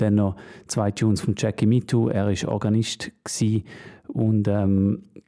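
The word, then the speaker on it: Jackie Mittoo: Ähm, ja, ich habe es vorher glaub, falsch gesagt. Jackie Mitu, mu musikalischer Direktor im, im Studio One.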